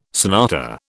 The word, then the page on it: sonata: British English